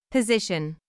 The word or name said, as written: position